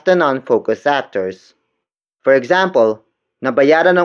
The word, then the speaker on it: nabayaran: Nabayaran ang utang namin. Nabayaran ang utang namin. The first word is nabayaran. Nabayaran. Nabayaran. Nabayaran is spelled as N-A-B-A-Y-A-R-A-N.